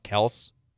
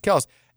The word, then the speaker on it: Kelce: But they were, I guess they had their dad on recently, and he revealed that their name is supposed to be pronounced Kelce. – No. – The family name. – Stop it. – And somebody, like, there's a clip of a Chiefs player on the sidelines being like, we've been saying it wrong. He's like, it's Kelce. It's Kelce.